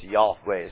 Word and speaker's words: Yahweh: I mean, it's Yahweh is how you ought to pronounce it, but I'll accommodate you.